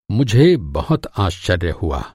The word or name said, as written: आश्चर्य